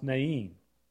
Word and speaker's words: Nain: Soon afterwards, Jesus went to a town called Nain. In some places, it's pronounced Nain.